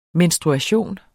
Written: menstruation

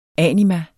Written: anima

